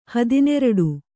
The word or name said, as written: ಹದಿನೆರಡು